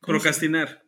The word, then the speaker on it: procrastinar: Eh, ¿Cómo se dice? Procastinar, mm. procastinar procrastinar. Procrastinar, sí.